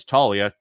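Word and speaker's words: Taglia: Before we hit our final player here, a couple guys from Colorado, Michael Talia, and we found out it's Talia. Just don't pronounce the G at all in his name.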